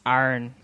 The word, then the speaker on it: iron: Like, it's arn. – Arn. – Arn. – Hmm. – Just A-R-N.